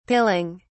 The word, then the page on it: pilling: British English